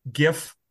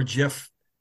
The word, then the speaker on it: GIF: You guys can have whatever GIF or GIF famous GIF reaction you want. – I'm still a hard G on that one.